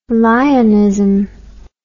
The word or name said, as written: lionism